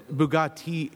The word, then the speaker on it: Bugatti: Bugatti is... – It's Bugatti. – Bugatti. – Yeah. – Is that... Now, but that's spelled the same. – It's spelled exactly the same.